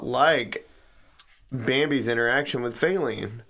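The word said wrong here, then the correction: Faline: Say her name one more time. – Failing? – One more time. – Feline? – Feline. – Feline. It's Feline.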